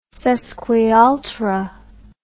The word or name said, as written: sesquialtera